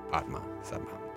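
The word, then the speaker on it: Padmasambhava: Padma Sambhava. Yeah, it was good pronunciation. That was really good.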